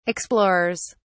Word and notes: explorers: British English

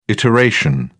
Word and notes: iteration: British English

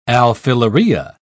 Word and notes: alfileria: British English

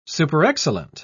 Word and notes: superexcellent: British English

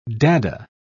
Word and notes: dada: English; British English

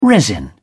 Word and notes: resin: British English

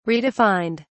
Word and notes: redefined: British English

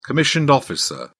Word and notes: commissioned officer: British English

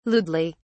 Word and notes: lewdly: British English

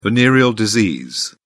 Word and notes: venereal disease: British English

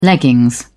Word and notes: leggings: British English

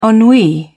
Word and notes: ennui: British English

